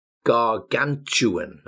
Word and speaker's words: gargantuan: Next, gargantuan. Now be really, really careful. Gargantuan. Gargantuan means really enormous, very, very large.